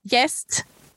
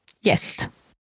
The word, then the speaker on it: gäst: So gäst is guest. It sounds pretty similar, doesn't it?